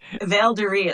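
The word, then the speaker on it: Valderi: Well, I don't know if it taught me that, but I took, okay, Valduri is how you say it, from the Happy Wanderer.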